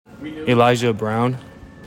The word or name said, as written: ELIJAH BROWN